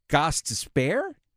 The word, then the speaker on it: Gostisbehere: Shane Gostisbehere? – Gostisbehere. – You got any change to spare? – Gostisbehere.